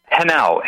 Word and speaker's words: Henao: Henao, H-E-N-A-O, pronounced Henao.